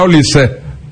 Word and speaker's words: Olise: Oye, el primero que apostó por Olise y no Olis fue Melchor Ruiz, una vez más de la cadena COPE, ¿eh? Sí, que la gente decía, Melchor se ha relajado con el tema de las pronunciaciones, pero no, otra vez acertó, era Olise.